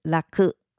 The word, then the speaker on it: らく: 第一种是拉克，拉克，拉克。